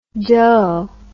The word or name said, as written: doh